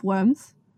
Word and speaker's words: Worms: Okay, so Luther is then summoned to appear at the Diet of Worms. Worms. I got that. Worms. – The what? – The Diet of Worms. It's spelled like worms, but it's German.